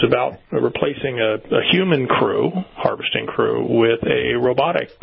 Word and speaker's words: Croo: Harvest Crow Robotics, C-R-O-O. What's the impact of that? Crew. What is it? Harvest, how do you pronounce it? – Crew. Harvest Crew. – Harvest Crew. As in C-R-E-W, in a manner of speaking.